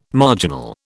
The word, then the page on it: marginal: British English